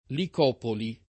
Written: Licopoli

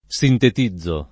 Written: sintetizzare

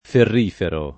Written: ferrifero

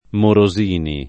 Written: Morosini

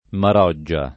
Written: Maroggia

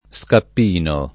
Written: Scappino

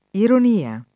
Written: ironia

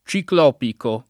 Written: ciclopico

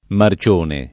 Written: Marcione

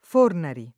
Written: Fornari

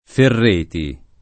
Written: Ferreti

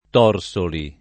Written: Torsoli

